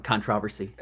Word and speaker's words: controversy: You gotta pronounce it like the British way. Controversy.